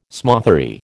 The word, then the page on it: smothery: American English